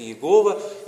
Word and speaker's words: Иегова: Это имя звучит как Иегова, или вернее мы потеряли его настоящее истинное звучание, многие ученые сегодня произносят его как Яхве,